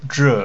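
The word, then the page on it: zhè: fourth tone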